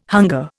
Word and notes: hunger: Indian

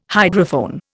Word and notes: hydrophone: Indian

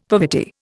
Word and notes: poverty: Indian

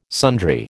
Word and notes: sundry: American English

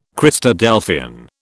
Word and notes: Christadelphian: British English

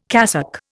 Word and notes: cassock: Indian

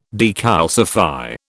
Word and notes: decalcify: British English

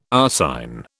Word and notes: arsine: British English

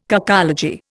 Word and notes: cacology: Indian

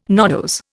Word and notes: nodose: Indian

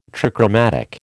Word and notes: trichromatic: American English

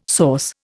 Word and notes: sauce: Indian

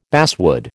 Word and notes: basswood: American English

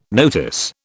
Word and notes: notice: British English

